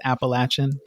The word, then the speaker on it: Appalachian: There is some discussion about pronunciation. I'm told it's Appalachian, but you know, we can talk about that too.